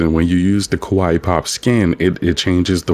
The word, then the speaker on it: Kawaii: It's like the word Hawaii, but spelled with a K because it's like K-pop Hawaii. So it's Kawaii Pop.